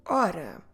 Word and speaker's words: hora: Ora. H, O, R, A. Ora. Humano. H, U, M, A, N, O. Humano. Let's go through them again and make sure you don't pronounce the H at the beginning.